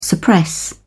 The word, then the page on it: suppress: British English